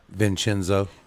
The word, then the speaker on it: Vincenzo: My guest is Giovanni Bruno, and how do you say your name, Vincent? – Vincenzo.